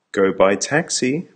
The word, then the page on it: go by taxi: American English